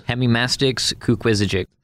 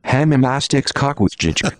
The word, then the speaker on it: Hemimastix kukwizijik: And he pronounced it like this. – Hemimastix kukwizijik. – And then it's supposed to be pronounced like this. – Hemimastix kukwizijik. – What an idiot. Wow. I mean, it's embarrassing for me to hear how wrong he got that.